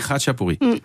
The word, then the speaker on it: Khachapuri: Et mais on dit Khachapuré.